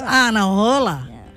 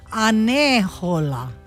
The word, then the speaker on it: Anahola: It's Anahola. Yeah. You know, there is also another pronunciation that says it's Anehola. – Oh. – So what, Nalani? How's that one? It's A-N-E-H-O-L-A in some of the old documents that we found.